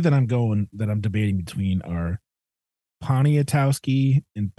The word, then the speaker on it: Poniatowski: Crap. Poniatowski. – Poniatowski. – Poniatowski. – Do that again. – Poniatowski, I think. – Poniatowski. – Poniatowski. – Yeah, the last two syllables are like the last seven letters.